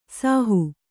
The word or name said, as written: ಸಾಹು